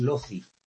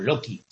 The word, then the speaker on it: loci: El plural es loci o loci, si lo pronunciamos en latín clásico. La C como fonema K, ¿no?